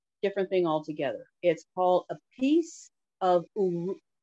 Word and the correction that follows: Uluru: Let me get this right. Uluru rock. Piece of Uluru.